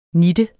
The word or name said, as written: nitte